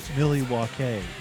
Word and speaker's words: Milwaukee: Actually, it's pronounced Milwaukee, which is Algonquin for the good land.